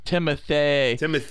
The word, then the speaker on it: Timothée: You gotta really pronounce that extra E with the accent aigu over it. – Timothée.